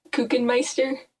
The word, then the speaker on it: Kuchenmeister: It's pronounced Kuchenmeister. – Okay. – Like Cookie Monster.